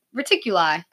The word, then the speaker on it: Reticuli: Reticuli. – Okay. – Yeah. – So we'll go with that. What's cool about Betty's star map is that it looks almost identical to the Zeta Reticuli star system.